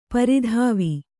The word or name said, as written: ಪರಿದೇವ